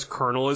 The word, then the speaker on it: colonel: All the queens botched the word colonel because colonel is spelled completely differently than it is pronounced.